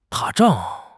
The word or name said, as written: 打仗